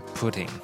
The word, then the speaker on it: Putin: 普丁他的名字叫做 Vladimir Putin，Vladimir Putin。 OK，那真的是听起来蛮像布丁的英文哦，对不对？Putin，难怪会有人把它翻成布丁。